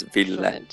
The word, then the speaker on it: Ville: It is. That was perfect. So it is Ville.